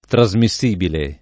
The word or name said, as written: trasmissibile